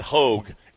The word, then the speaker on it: Hogue: Right now, we got Hogue. H-O-G-U-E. That's how you spell Hogue, all right? With a silent U.